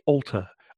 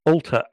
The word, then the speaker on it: Altar: It's actually Altar. Altar as in church.